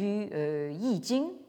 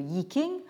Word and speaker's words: Yi Jing: Mais comme vous le savez peut-être, Wilhelm est surtout connu pour sa traduction du Yi Jing, ou comme on prononce ici le Yi King, ou le livre des mutations, sous le titre allemand donc Das Buch der Wandlungen, donc le livre des transformations.